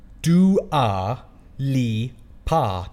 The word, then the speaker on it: Dua Lipa: And he wrote it as Dua Lipa.